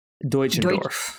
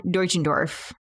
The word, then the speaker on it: Deutschendorf: Deutschendorf. – Deutschendorf. – Deutschendorf? – If it's pronounced like Deutschland, like Germany in German, okay. Yes. – Deutschendorf is my guess.